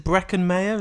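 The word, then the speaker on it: Breckin Meyer: And then is it Brecken Mayer? Is that how you say it? – Yeah.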